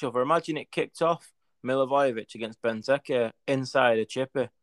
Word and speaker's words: Milivojevic: Milivojevic.